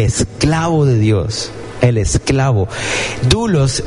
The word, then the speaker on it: doulos: La palabra griega es doulos. Se escribe D-O-U-L-O-S.